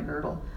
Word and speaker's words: girdle: girdle. This is actually how Scots pronounce griddle. It's a girdle.